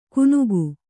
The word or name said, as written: ಗುನುಗು